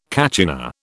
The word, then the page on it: kachina: British English